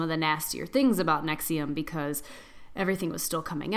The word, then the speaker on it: NXIVM: So I did the Nexium cult, or it's spelled N-X-I-V-M, like Roman numeral style.